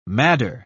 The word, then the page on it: madder: British English